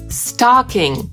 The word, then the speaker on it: stocking: Ale już po amerykańsku ta pończocha to stalking, czyli szerokie a. I po amerykańsku tak się składa, że te dwa słowa to homofony, czyli wymawia się dokładnie tak samo. I świąteczną skarpetę albo pończochę i stalking.